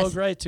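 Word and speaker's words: Bo Gritz: Am I saying it right? – I think it was, yes, Bo Greitz.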